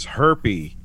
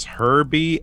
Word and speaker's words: Herbie: She refers to him as Herpy, even though his name is Herbie. Everybody else pronounces it with a B. She always pronounces it with a P.